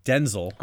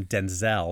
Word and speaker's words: Denzel: Yes, which is, I always notice, this is one of those weird, you know, tangents, but I always notice that the Brits call him Denzel. – Oh, yeah. – And Americans, we call him Denzel. Like, we put the emphasis on the second syllable.